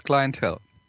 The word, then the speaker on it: The Clientele: Heißt es The Clientele oder heißt es The Clientele? Weißt du das? Bist, bist du da? – Also äh, auf Englisch wäre es Clientele.